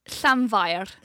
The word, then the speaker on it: Llanfair: So the first bit is Llanfair. – Llanfair. – Oh, lovely. Yeah, Llanfair.